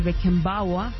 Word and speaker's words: Beckenbauer: Y por el otro lado, en el grupo B, la Alemania Federal de Beckenbauer, que está bien pronunciado.